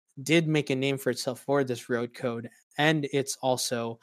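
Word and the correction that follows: REDCODE: You mean Red Code, not Road Code? – I'm sorry, I said that wrong.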